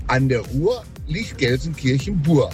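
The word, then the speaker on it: Gelsenkirchen-Buer: Dankeschön für zahlreiche Unterstützung bei der Eselsbrücke zum Namen Gelsenkirchen-Bur, was mit UE gesprochen, geschrieben wird, aber U ausgesprochen wird.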